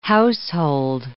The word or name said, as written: household